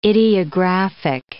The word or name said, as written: ideographic